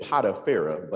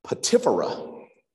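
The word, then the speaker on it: Potipherah: the daughter of I always said Potipharah, but that's just the way we pronounce it. But apparently it's pronounced Potipharah, the daughter of Potipharah, the priest of On.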